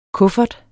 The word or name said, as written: kuffert